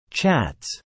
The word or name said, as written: chats